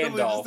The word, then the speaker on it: Gandalf: It's Literally Gandalf. just a couple of letters. Gandalf.